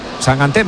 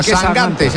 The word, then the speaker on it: Sangante: No, sangante. Se pone sangante. – Yo pronuncio como me digáis. – Bueno, eso lo he oído yo, que dice que es Sangante. – De hecho, me ha dicho, tú acuérdate de Mangante.